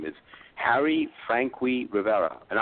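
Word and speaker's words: Franqui: Frankie is spelled F-R-A-N as in Nancy, Q-U-I. So it's Harry Frankie.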